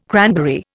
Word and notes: cranberry: Indian

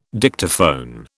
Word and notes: Dictaphone: British English

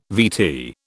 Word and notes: VT: British English

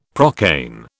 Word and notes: procaine: British English

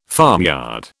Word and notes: farmyard: British English

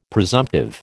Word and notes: presumptive: American English

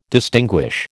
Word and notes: distinguish: American English

